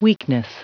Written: weakness

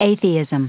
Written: atheism

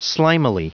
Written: slimily

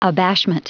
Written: abashment

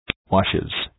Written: washes

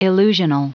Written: illusional

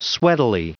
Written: sweatily

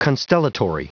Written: constellatory

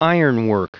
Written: ironwork

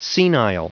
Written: senile

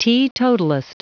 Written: teetotalist

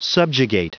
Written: subjugate

